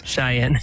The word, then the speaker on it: Cheyenne: God, my goodness, I cannot pronounce Cheyenne. – These words. – Cheyenne.